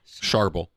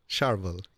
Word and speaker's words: Charbel: Charbel. – Charbel.